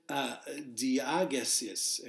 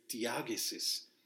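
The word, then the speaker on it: diegesis: Now that Greek word, let's take a look at that Greek word because it's pronounced diagesis. And forgive me again, I always butcher the Greek, diagesis. Okay, it's D-I-E-G-E-S-I-S.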